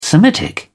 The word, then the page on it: semitic: British English